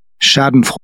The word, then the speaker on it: Schadenfreude: Schadenfreude.